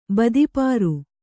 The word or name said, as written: ಬದಿಪಾರು